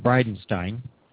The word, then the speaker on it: Bridenstine: Bridenstine. – Okay. – That's B-R-I-D-E-N-S-T-I-N-E.